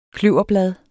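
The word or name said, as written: KLØVERBLADE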